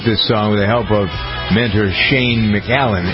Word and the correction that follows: McAnally: Who's this? McAnally? How do you pronounce it? Shane McAnally.